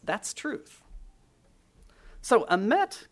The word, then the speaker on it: emet: Now, this word for truth comes from the Hebrew word emet, E-M-E-T, if you want to spell it, E-M-E-T.